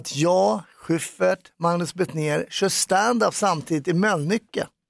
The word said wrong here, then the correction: Mölnlycke: Du uttalar Mölnlycke väldigt konstigt. Det är som med Mölndal, att ni utifrån kan vara... Säg Mölnlycke. – Mölnlycke. Du liksom uttalar för mycket L. Mölnlycke säger man mer.